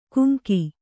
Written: ಗೆಂಕು

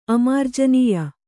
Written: ಅಮಾರ್ಜನೀಯ

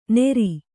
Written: ನೇರಿ

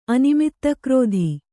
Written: ಅನಿಮಿತ್ತಕ್ರೋಧಿ

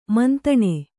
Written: ಮಂತಣ

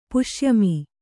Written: ಪುಷ್ಯಮಿ

